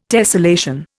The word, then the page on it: tessellation: Indian